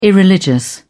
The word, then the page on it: irreligious: British English